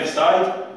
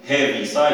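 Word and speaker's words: Heaviside: اما اغلب الناس في المراجع اللي نعرفهم نتاع الرياضيات يسميوها اف سايد بالاتش اسمه هيفي سايد اما الاتش ما تتنطقش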